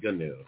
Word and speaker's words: GNU: They pronounce it new, but I say GNU.